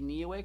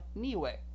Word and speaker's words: Niue: Niue. Niue. You'll hear it pronounced several different ways. Niue, N-I-U-E, is a protectorate of New Zealand.